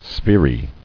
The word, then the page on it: Sphery: spher·y